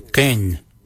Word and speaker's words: Geny: For 1, we say ken. Ken means 1. Ken is spelled G-E-N-Y, ken.